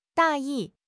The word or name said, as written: 大意